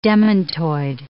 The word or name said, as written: demantoid